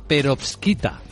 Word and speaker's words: perovskita: Bueno, esto tiene que ver con Asia, esta historia, que es una de las más llamativas de esta mañana, la perovskita. Lo he dicho bien, Laura Blanco. – Lo has dicho perfectamente.